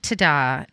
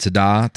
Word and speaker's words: Tiede: I think it's Tada. Bernie Tada. T-I-E-D-E is how it's spelled.